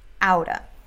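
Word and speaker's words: out of: E a pronúncia não é kind of. Não tem pausa. E nem fala of v, no final. Kinda, sorta, outa.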